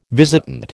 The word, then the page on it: visitant: American English